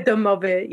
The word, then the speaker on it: domovoy: Is that how you say it, domovoy? – The domovoy, yes.